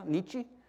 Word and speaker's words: Nietzsche: Nietzsche? Is that how you say it? Yeah.